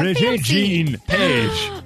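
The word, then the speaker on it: Regé-Jean Page: Rajay Jean Page.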